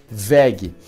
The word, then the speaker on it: WEG: WEG é como se escreve, W-E-V, mas se lê VEG.